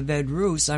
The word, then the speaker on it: Vedruss: V-E-D-R-U-S-S.